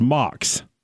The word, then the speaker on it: Max: You think that would be Max, but the German pronunciation is Max.